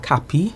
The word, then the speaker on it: CAPI: Okay, so we pronounce the acronym CAPI, and it stands for Computer Assisted Personal Interviewing.